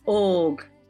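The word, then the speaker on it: Óg: Have I pronounced that correctly? – Og. – All right, I came close.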